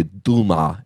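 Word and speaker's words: Duma (Дума): In Russia, the parliament, there are no seats in the Duma, as it's called. That's how you say it in the traditional Russian.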